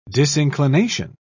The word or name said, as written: disinclination